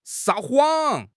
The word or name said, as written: 撒谎